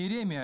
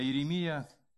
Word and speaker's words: Иеремия: Не Неемия, не Иемия, не Иеремия, а Иеремия, не Иезекия, а Иезекия.